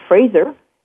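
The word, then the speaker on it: Frazer: So in order to reach me on Facebook, it's Facebook/ The Raw Truth Radio Show, on Twitter / Chef Fraser,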